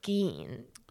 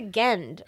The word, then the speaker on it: Gein: And she's to this day, she, like, says his name wrong sometimes. – Yeah, like, Gein? – She has a Gend. I'm like, that's not his name.